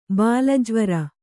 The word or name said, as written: ಬಾಲಜ್ವರ